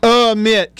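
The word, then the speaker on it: Amite: Am I saying that right? Amit? Amit? Amit? Which one is it? A or a? Beaver, how do you say it? Amit County. Thank you, sir.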